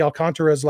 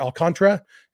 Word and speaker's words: Alcantara: I know I've been, I used to say Alcantara, and somebody corrected me and said the emphasis is on the con, and I think I've been then running the last two syllables together, saying more Alcantara, and said it should be Alcantara. And I'm not good at rolling my R's, but at least getting all four syllables.